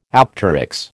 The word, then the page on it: apteryx: American English